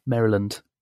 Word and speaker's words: Maryland: I always pronounce it as Maryland. – Oh, and it's Maryland for them, is it? – Maryland.